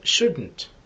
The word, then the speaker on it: shouldn't: Should not в беглой речи произносится shouldn't.